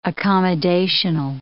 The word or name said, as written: accommodational